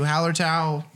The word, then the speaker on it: Hallertau: Hallertau, that's not how I said it the first time, but that is the correct way to say it.